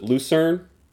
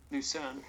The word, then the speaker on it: Lucerne: Luzerne? – Lucerne.